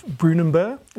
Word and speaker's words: Brunanburh: But previously you mentioned this big battle towards the end of his reign, and that's the Battle of Brunanburh.